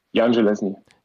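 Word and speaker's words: Jan Železný: Wie würdest du ihn aussprechen aus Tschechien? Jan Geleschny. Jan Geleschny, alles klar, darauf können wir uns einigen. Traust du dir auch den Weltrekord zu?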